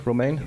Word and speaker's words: Romain: Toussaint Romain, did I pronounce that correctly? Romain? – Yes.